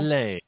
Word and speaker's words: Ghislaine: Ghislaine. We don't pronounce all the letters, yes.